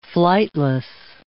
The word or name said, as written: flightless